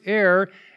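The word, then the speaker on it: air (ἀήρ): There are three of them, and the Greek word that's used here is air. It's actually alpha, eta, rho, if you're interested in what the Greek letters are. And it's pronounced air, and this one has the idea of a space that's immediately over the earth.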